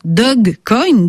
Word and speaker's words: Dogecoin: C'est alors qu'un duo d'ingénieurs américains met au point Dogecoin pour se moquer de la Bitcoin mania. Certains prononcent d'ailleurs Dogecoin, Dog comme chien, puisque cette monnaie virtuelle a l'effigie d'une tête de chien de la race nippone Shiba Inu.